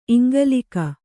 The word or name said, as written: ಇಂಗಲೀಕ